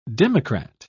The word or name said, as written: democrat